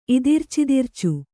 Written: ಇದಿರ್ಚಿದಿರ್ಚು

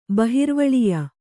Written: ಬಹಿರ್ವಳಿಯ

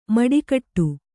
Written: ಮಡಿಕಟ್ಟು

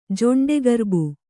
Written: ಜೊಂಡೆಗರ್ಬು

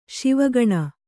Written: ಶಿವಗಣ

